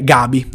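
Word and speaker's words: Gavi: 18ª posizione che secondo me dovrebbe occupare Gabi del Barcellona. Attenzione, lui si pronuncia Gabi essendo proprio un giocatore spagnolo, e la V in spagnolo non si pronuncia ma si dice la B,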